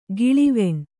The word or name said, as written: ಗಾಳುವೆಣ್